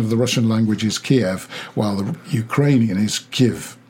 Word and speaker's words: Kyiv: K-Y-I-V and K-I-E-V. – Yeah. – You have to be congratulated on your pronunciation. Your Kyiv was perfect.